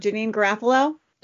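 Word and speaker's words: Janeane Garofalo: I'm saying her name wrong. Janine Garofalo? – Yeah. Mm-hmm.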